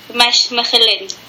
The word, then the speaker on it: Mechelen: but it's Mechelen.